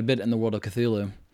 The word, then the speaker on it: Cthulhu: so it's like even Cthulhu, but it's supposed to be like two syllables, like Hulu, but he designed them in a way where they're not supposed to be able to be pronounced by us.